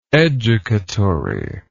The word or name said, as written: educatory